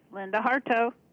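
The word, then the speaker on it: Linda Hartough: Am I pronouncing your last name right? – Close enough. Linda Hartaugh.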